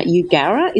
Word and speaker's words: Eugowra: So we're speaking to you today and you're on a farm near Eugowra. Is that the right way to pronounce it? – Yes, spot on.